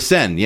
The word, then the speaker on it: Seine: The International Olympic Committee continues to put athletes' health in danger by continuing to insist that it's safe to swim in the serene water. – Oh, gosh. It's, oh, the Seine, yeah.